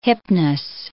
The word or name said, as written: Hypnos